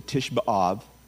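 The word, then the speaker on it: Tisha B'Av: It was called that long before Titus came in, because that was the day, the same day, the Tish B'Av, which is how you say it in Hebrew, the ninth of Av, that Nebuchadnezzar destroyed the temple the first time.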